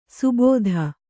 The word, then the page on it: ಸುಬೋಧ: subōdha